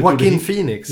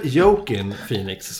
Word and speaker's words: Joaquin Phoenix: Joaquin Phoenix. Ja. För hans... Inte Jokin Phoenix som en del säger. Eller... Joaquin. Joaquin. Ja, precis. Joaquin.